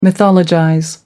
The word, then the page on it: mythologize: American English